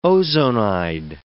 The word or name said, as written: ozonide